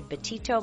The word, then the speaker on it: Betito: You can connect with me on social media at Dr. Lori Betito. My last name is spelled B-E-T-I-T-O.